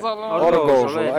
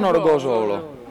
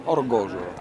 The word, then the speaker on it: Orgosolo: Allora, prima di tutto tu devi imparare a dire Orgozolo, ecco, non Orgozolo. Orgozolo.